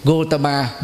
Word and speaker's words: Gautama: Cho nên gọi theo cách Ấn Độ là hay nhất, Gautama Buddha. Gautama là họ của Đức Phật, nhưng mà phát âm theo tiếng Ấn Độ là Gautam, chữ A nằm ở cuối không có đọc, Gautam Buddha.